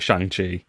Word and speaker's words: Shang-Chi: Like obviously they're on the press junket for Shang-Chi, should we say, because that's how they say it in the film.